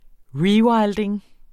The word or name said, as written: rewilding